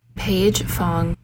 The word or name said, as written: paige fong